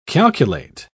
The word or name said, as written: calculate